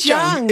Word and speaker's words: Jung: So it's not Chung. I thought it was Chung, but it is Chung.